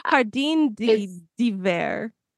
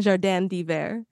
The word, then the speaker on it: Jardin d'hiver: You didn't even try to pronounce that last one. – Nope. Jardin d'hiver. The Jardin d'hiver.